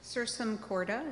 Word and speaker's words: Sursum Corda: I haven't done this yet, but I was just reading this, the Sursum Corda, is that how you pronounce it? – That's right.